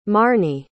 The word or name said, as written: marny